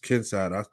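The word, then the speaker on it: Kincaid: How you say it? Kinside, whatever. – Yeah, just Kinside. That's fuck it. That's how you want to say it.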